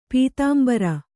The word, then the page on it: ಪೀತಾಂಬ್ರ: pītāmbra